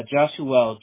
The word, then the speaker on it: Joshuell: J-O-S-H-U-E-L-L? – Yes. – Wow. It's very unique. How do you say it? Joshua? – Yep.